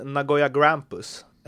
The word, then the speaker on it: Nagoya Grampus: Hur, eh, du spelar ju alltså för Nagoya, alltså jag vet inte om man ska uttala det på något annat sätt, men eh, Nagoya Grampus, är det så? – Ja, det stämmer. Ja, det hörs bra.